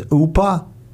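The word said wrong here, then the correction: Opa (ώπα): Opa, opa. – Oop. Can you say it? Say it. – No, opa, opa. – Opa. – Say it. – Opa. – Opa, yes. – Opa. – O-P-A. O-P-A.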